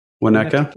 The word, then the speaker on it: Winnetka: Winnetka.